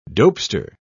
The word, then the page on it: dopester: British English